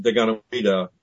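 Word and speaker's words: Deganawida: Well, his name is, it's pronounced Daganoweda, but the Iroquois are sensitive about uttering the name.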